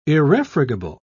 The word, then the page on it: irrefragable: British English